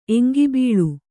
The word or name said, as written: ಎಂಗಿಬೀಳು